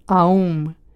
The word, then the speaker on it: Om: O famoso OM é a palabra sánscrita AUM, que debe ser pronunciada atendendo moi especialmente aos resonadores vocais. Comeza o A no que se chama voz de peito. O U vai a gorxa, para subir ata a voz de cabeza coa pronunciación do M e acabar nun silencio.